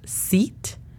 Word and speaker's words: seat: No hay que confundirla con el de sit down, porque aunque es muy poca la diferencia, sí la hay en la pronunciación. Una es seat y otra es sit. Esta significa asiento, aunque la pronunciación fonética sí es S-I-T, sit.